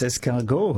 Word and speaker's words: escargot: I think it's escargot. Is that how you say it? – Yes, escargot, of course.